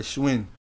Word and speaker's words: Shwinn: Yeah, Schwin. – Schwin? Because it look S-H-W-I-N-N.